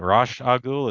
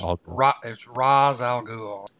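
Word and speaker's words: Ra's al Ghul: Well, no, I meant Rosh Agul. Is it Rosh Agul? – It's Raz Al Ghul.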